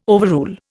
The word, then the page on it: overrule: Indian